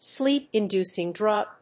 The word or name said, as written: sleep‐inducing drug